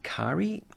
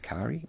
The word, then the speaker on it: Kairi: Kari. I think that's how you pronounce Kari. K-A-I-R-I, Kari, in the western...